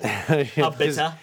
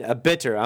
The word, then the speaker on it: Abita: However, I pronounce it as right. – Abita. – Because if you're saying Abitter, I'm thinking of a style of. – No, not Abitter.